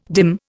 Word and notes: dim: Indian

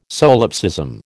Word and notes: solipsism: American English

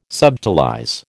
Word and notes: subtilize: American English

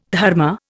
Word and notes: dharma: Indian

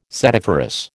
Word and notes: setiferous: American English

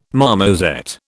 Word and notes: marmoset: British English

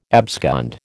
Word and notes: abscond: American English

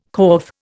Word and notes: corf: Indian

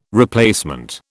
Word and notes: replacement: British English

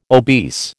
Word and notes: obese: American English

